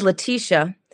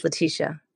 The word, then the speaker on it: Laticia: So in the Greek culture, it's spelled L-E, like Laticia. But my mother changed the E to the A, and it's Laticia.